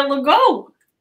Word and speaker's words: Legault: You can actually pronounce it like the Lego blocks. So Tanya Legault.